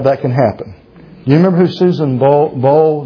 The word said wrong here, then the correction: Boyle: Did I say her name right correctly? Bowl? Boyle, thank you. I didn't think Boyle. Boyle, thank you.